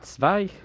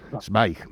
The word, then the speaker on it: Zweig: Sí, a ver, Antonio, para que tengas toda la información, le he pasado escrito Stefan Zweig, que no sé decirlo, porque como tiene un alemán estupendo y perfecto, al final escribió Stefan, ¿cómo se dice, el apellido? – Zweig. – Zweig. Escribió Zweig.